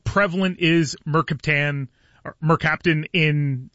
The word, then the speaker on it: mercaptan: Is it mercaptan? How are we pronouncing it, and what is it? – It's called mercaptan.